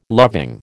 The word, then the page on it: loving: American English